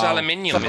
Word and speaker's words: aluminium: It's pronounced aluminium, isn't it? – Well, you know, it's called aluminum, the English language, mate, and we've decided to change it to aluminium.